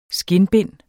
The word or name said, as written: skindbind